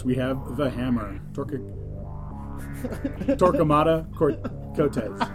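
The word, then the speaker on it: Coteaz: Yeah, there's no R in there. Cotias. – Yeah, I don't know how to spell it. – I think that is Cotez. I'm pretty sure that's what it is. It's just the Cotez. Without the without the R.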